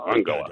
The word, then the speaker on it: Angela: I like the German pronunciation. Angela. – Angela.